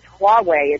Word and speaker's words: Huawei: but it's Huawei. It's H-U-A-W-E-I.